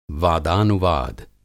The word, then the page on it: वादानुवाद: Hindi